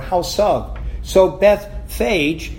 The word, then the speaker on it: Bethphage: It is so interesting that you'll find this word pronounced in so many different ways by so many different preachers. Bethphage, Bethphage, Bethphage. As I understand it, the best way to pronounce it is Bethphage.